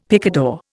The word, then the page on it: picador: Indian